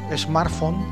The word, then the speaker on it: smartphone: acceden con su smartphone, ¿lo he dicho bien? – Sí.